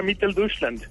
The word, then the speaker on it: Mitteldeutschland: Pues creo que se pronuncia Mitteldeutschland.